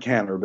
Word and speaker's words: Kanner: It looks like Kanner, but it's pronounced Kanner.